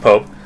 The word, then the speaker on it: POAP: At my school, we have an organization known as POAP, P-O-A-P, pronounced Pope, a.k.a.